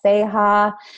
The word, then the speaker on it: Ceja: Jeanette is spelled J-E-A-N-N-E-T-T-E. – Yes. Okay. Yes. And at Twitter, it's my first and last name, Jeanette Seha. – Seha is C-E-J-A.